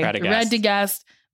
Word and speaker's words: Radagast: Okay, so the wizard Rastafarian and the brown finds Radagast. Is that how you say it? – That's what I would say. – Radagast.